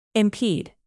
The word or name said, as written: impede